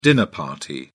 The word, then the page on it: dinner party: British English